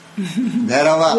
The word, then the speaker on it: Bhairav: Yeah. Indian is Bhairav. – Yes. I would have said Bhairav. – Bhairava. – Would that have been... – Bhairava, Bhairav. Thank you. Thank you for telling me how it's pronounced.